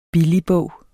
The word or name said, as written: BILLIGBOG